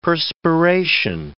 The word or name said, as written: perspiration